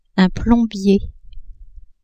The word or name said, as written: plombier